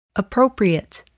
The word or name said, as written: appropriate